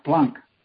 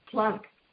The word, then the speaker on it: Planck: How do you say his name? – Max Planck. – Max Planck.